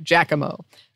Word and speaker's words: Giacomo: Every Italian will argue that their dialect and region are the correct ones, but I've come to learn that the pronunciation is more like Giacomo. Now, don't say it too fast. The O is drawn out to a long O sound. oh, now I'm in my head. Um, sometimes the Jack part sounds just like Jacques with the J sound.